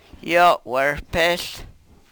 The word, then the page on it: yo we-rpehl: Yurok